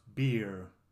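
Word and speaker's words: bjórr: In the Nordic mythology, the gods drank beer, which is spelled B-I-O-R, and pronounced beer, beer.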